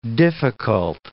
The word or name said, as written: difficult